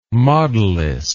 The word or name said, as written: modelist